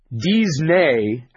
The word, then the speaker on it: Disney: Disney, as the French would pronounce it, is Eisner without the D.